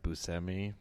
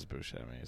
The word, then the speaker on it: Buscemi: Actually pronounced Buscemi, but popular is Buscemi, so I'm just going with that because I want to be popular.